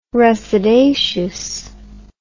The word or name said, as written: resedaceous